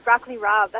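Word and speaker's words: broccoli rabe: Broccoli rab. Is that how you pronounce it? Rab? – Oh, yeah. Broccoli rab. That's my favorite.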